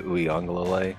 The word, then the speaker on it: Uiagalelei: I'm completely not sold on DJ Uiagalelei. Did I say that right? I think I nailed it, actually.